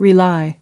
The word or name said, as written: rely on someone/something